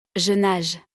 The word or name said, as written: je nage